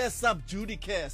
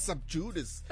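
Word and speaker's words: sub judice: Subjudice. And then you hear subjudicare sometimes, but it's subjudice.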